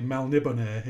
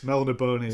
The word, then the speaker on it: Melniboné: Apparently Melniboné is the correct way of pronouncing it. – It's Melniboné. – It's Melniboné. Melniboné.